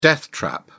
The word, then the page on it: death trap: British English